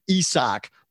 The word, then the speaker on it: Isaac: I know you wrote about Isaac Pitbull Cruz, Isak, the Hispanic-Mexican pronunciation, Isak Pitbull Cruz, with his win over Gamboa on that card.